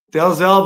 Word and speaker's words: Dalzell: Dalzell, buddy. Yes, sir. I like to pronounce all the letters. Apparently, it was a Scottish name, and okay. So pronounced it Dazzle, but...